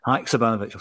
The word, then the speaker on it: Haksabanovic: How do you say the new guy's name? Hacks kind of Haksabanovic. – Haksabanovic. I'll say that three times.